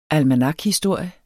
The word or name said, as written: almanakhistorie